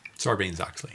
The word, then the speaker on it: Sarbanes-Oxley: Sarbanes-Oxley.